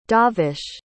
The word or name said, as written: davesh